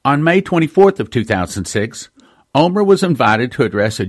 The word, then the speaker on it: Olmert: On April 6th, Omer, spelling O-L-M-E-R-T, but pronounced Omer, was officially asked by President Moshe Katsav to form a government.